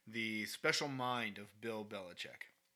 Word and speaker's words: Belichick: Bill Belichick, or Belichick, as he says, that's the way you're supposed to announce it, or pronounce it, excuse me. But Belichick, because that's the way everybody else does it, he's greatest coach all time.